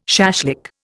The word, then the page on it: shashlik: Indian